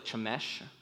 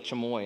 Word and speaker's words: Chemosh: I'm going to say it wrong. It's like Chemosh. I only remember it as like Chemoy, and I know that's wrong.